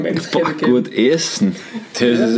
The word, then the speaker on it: Enschede: So, jetzt hätte ich gerne von dir, ich wollte eigentlich Essenstipp von dir haben, wo man in, wie heißt das, Enschede, wie spricht man das aus? wo du in Holland, Enschede. Enschede.